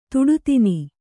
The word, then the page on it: ತುಡುತಿನ್: tuḍu tin